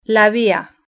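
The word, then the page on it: la via: Spanish (Spain)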